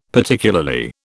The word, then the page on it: Particularly: British English